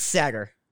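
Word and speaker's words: Saagar: I don't know how you pronounce it. – it's Sagar.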